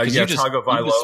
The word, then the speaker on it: Tagovailoa: Is it Tagovailoa? Is that how it's pronounced? – Yeah, Tagovailoa.